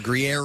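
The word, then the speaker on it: Gruyère: I uh, it's spelled G R U Y E R E, and the woman had told me just think green air. That's the pronunciation. Gruyere, green air.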